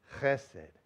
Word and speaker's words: Chesed: And, you know, and the C-H is like loch, you know, the Scottish loch. Sounds like you're clearing your throat. Chesed. Chesed.